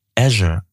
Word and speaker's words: Azure: Pascal schreibt, liebes AAA-Team, als kleiner Hinweis, es wird Microsoft Azure ausgesprochen. Ja, tatsächlich habe ich, glaube ich, gestern gesagt, diese Cloud-Sparte heißt Azure. Sie heißt nicht Azure, sie heißt also Azure.